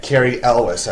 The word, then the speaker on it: Cary Elwes: He was asked, the actor of the movie who played that, Cary Elwes, I believe his name.